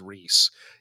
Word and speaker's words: Reese: But if you fucking let it go for, you know, 3.5 years, actually, Tommy, my name is Reese. Yeah. Like you're going to embarrass the fuck out of them. – I genuinely hope to build a 15-year friendship with someone who gets my name wrong. And then at my funeral, then when they act, someone stands up and says my name, they go, what? I've been calling him Rise this whole time.